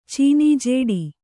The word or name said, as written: ಚೀನೀಜೇಡಿ